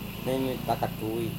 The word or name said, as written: nemeta-katui